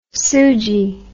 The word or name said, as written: suji